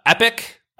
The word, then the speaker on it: epoch: Another one, which I added due to a coworker had mentioned it, was I say epic, E-P-O-C-H, or epoch was very common as well, based on like, you know, computer time counting. Fisk, F-S-C-K, was probably one of my favorite ones because it was incredibly varied.